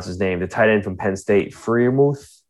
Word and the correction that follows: Freiermuth: Freiermuth, yeah. Freiermuth, I think he's going to be a very good pick in the second round, whoever gets him.